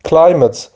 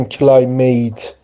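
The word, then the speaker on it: climate: کنسرن، این کلایمت میتی که آخر کلمه است، میت تلفظ نمیشه، حالا کلمه میت رو داریم ولی این ام ای تی ای نگیم کلایمیت، تلفظ درست چیه؟ کلایمت یا کلایمت، یعنی ای کوتاه یا ا یا همون شوا تلفظ میشه، کلایمت نگیم کلایمیت.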